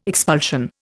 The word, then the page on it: expulsion: Indian